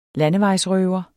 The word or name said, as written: landevejsrøver